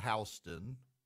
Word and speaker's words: Houston: There's a Houston County, but it's called Houston.